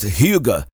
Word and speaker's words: hygge: I think it is a Nordic word pronounced Hygge.